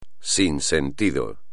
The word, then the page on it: sin sentido: espagnol